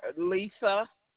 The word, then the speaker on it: Lisa: Lisa. – And it's spelled L-I-S-A? – Yes.